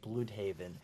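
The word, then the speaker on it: Blüdhaven: Bloodhaven. Showing up at Dick's apartment. – Oh my god, you said it right. – I did say it right, just for you.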